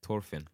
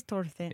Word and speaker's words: Thorfinn: They pronounce it in the anime, they pronounce it Thorfinn. – Yeah, it probably is Thorfinn.